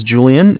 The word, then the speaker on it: Julian: Hi, my name is Julian. It's J-U-L-I-A-N.